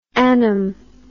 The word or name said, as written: annum